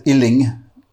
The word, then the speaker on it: Uilleann: Yeah, Uilleann is, some people mispronounce it as Uilleann, in that they make you sick. So that these, no, Uilleann is the Irish for elbow,